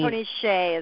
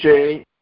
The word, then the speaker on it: Hsieh: Tony Hsieh is how he pronounces it. – Hsieh.